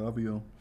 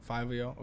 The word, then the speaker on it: Fivio: Fivio Foreign, is that right? Is that how you say his name? – Yeah, Fivio. Fivio, okay.